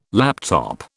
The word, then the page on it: laptop: British English